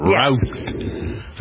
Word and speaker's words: Routh: Eddie Ray Routh, that's how you pronounce his name? – Yes. – Routh.